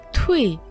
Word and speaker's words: 退: Слово «туй» произносится четвертым тоном.